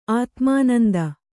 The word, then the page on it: ಆತ್ಮನಿಂದೆ: ātmaninde